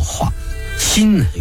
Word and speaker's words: 华歆: 曹睿也慌了，回头问那老臣华歆，华就是中华的华，读作华，歆那个音乐的音，右边一个欠债的欠。